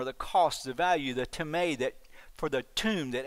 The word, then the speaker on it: timē: The Greek word is timē, T-I-M-A-T-A, T-I-M long E, timē.